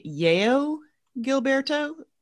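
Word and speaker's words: João Gilberto: Terry claims this is pronounced Ye- Yeo Gilberto, and of course Stan Getz.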